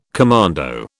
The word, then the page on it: commando: British English